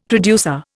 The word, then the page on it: producer: Indian